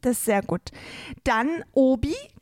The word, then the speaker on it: Obi: Also Hobby geschrieben, ja. Aber die Franzosen haben ja ein stummes H. – Sehr gut. Und weil Franzosen kein H aussprechen können, übernahmen sie kurzerhand die Lautschrift Obi, also Obi.